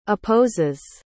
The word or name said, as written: opposes